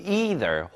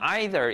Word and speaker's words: either: But either 혹은 either 이라고 읽기도 합니다. 지역에 따라서요. 혹은 나라에 따라서요.